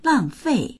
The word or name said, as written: lang4fei4